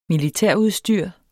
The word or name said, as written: militærudstyr